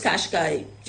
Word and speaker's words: Qashqai: You said Qashqai, that's the pronunciation of it? – Yes. – It is Qashqai.